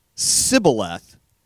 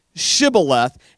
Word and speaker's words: Shibboleth: And the guy, if he answered, the next word that came out of his mouth was really important because if he replied Sibboleth, immediate execution because Ephraimites can't say that word right, okay? They can't pronounce it right. They say Sibboleth instead of Shibboleth.